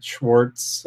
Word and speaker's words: Schwartz: Schwartz, S-C-H-W-A-R-T-Z is how you spell it.